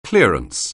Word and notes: clearance: anglais

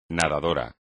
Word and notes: nadador: espagnol

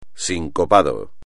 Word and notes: sincopado: espagnol

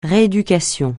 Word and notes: rééducation: francais

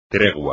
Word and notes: tregua: espagnol

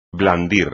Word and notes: blandir: espagnol

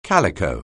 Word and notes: calico: anglais

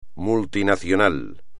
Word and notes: multinacional: Spanish

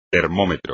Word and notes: termómetro: espagnol

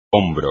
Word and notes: hombro: espagnol

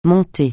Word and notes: mont-de-piété: francais

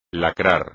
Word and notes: lacrar: Spanish

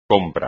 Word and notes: compra: espagnol

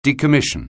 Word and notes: decommission: anglais

